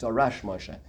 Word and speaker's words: Darash Moshe: People say it's the Drash. I think it's about used Darash Moshe is the correct way to say it.